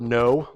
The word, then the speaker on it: know: And if you've ever wondered why the word know, like I know something, has a letter K in front of it, it's because of this.